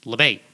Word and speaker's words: Labate: Yeah, it's an Italian name. But when they got off the boat in New York, they're like, we're Americans now, so how do Americans say it? Labate.